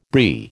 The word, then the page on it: Brie: American English